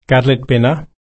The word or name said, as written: kadlitpinna